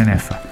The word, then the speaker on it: Neffa: Ho detto bene Neffa.